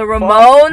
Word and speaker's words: Ramones: Oh, I can't say the band name right. Romanos? Romano? – The Ramones? – Ramones. I can never say the name right. – It's the Ramones. – The Ramones. It's so easy to say. – It has R-A-M-O-N-E-S, right? – Yeah, he said the Ramononos?